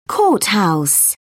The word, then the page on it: courthouse: British English